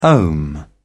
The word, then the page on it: ohm: British English